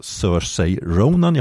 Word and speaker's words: Saoirse Ronan: Och då blir ju Mary of Scotland då, som spelas av Saoirse Ronan, jag hoppas jag uttalar namnet rätt. – Jag tror att du gjorde faktiskt.